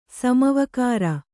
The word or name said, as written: ಸಮವಕಾರ